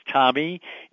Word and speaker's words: Tomie: Now, both names are kind of funny to spell because Tommy is T-O-M-I-E, which was a spelling I got as a child from my mother, but it's pronounced Tommy, like T-O-M-M-Y.